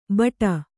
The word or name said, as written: ಬಟ